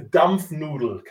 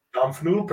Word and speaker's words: Dampfnudel: German specialty, it's called, pronunciation first, a Dampfnudel. Can you say Dampfnudel? – Dampfnudel? – Dampfnudel, perfect.